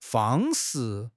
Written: fang1 shi4

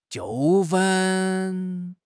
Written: 纠纷

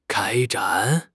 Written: 开展

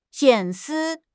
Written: 隐私

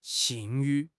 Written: 信誉

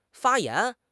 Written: 发炎

